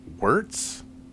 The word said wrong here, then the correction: Wort: Right? Am I saying that right? – Wort? – Yeah, that's I'm saying, shit.